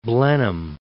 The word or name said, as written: Blenheim